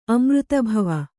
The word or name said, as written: ಅಮೃತಭವ